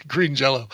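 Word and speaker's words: Green Jellÿ: And their comeback was changing the spelling of the name, but not the pronunciation. So it's spelled Green Jelly with umlauts over the Y, but still pronounced Green Jell-O.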